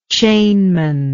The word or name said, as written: chainman